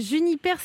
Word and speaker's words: JNPR: Ça s'appelle Juniper Spirit, mais ça s'écrit J-N-P-R Spirit, des spiritueux sans alcool, sans sucre, à découvrir.